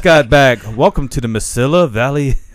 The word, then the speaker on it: Mesilla: I was like, hey, you know, this is how you say it. Mesilla Valley.